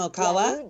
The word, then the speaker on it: Okawa: Okawa? – Yeah, pronounce it so well.